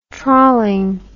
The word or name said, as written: trawling